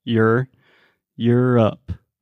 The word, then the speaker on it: Europe: So imagine you're like your, but with a really redneck, you're Europe. Like it's your turn. Es sua vez. You're up.